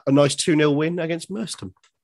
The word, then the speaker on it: Merstham: We got there, the pitch was pristine, lovely people at Merstham, we were told. To pronounce it Ollie Mers with a fat tummy. So Merstham, that's how we were told to pronounce Merstham.